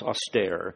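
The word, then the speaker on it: austere (ἀστήρ): In Greek, by the way, it is astar, austere. It could be better pronounced austere.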